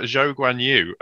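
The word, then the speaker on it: Zhou Guanyu: Um, but we have uh, Zhou Guan Yu. Um, that is apparently how he prefers to have it pronounced.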